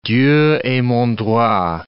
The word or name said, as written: Dieu et mon droit